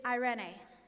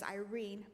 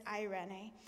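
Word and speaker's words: eirene (εἰρήνη): The Greek word in the New Testament for peace is eirene, or sometimes I think in our American language we pronounce it as Irene, but it's eirene.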